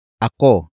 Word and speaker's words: ako: The next word is ako. Ako. Ako. Ako is spelled as A-K-O.